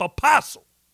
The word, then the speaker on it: apostle: the moniker of the hip-hop apostle. – No, you got to say it like you know, like you from Deep South. You got to put that T in there.